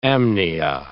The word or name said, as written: amnia